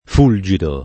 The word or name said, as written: fulgido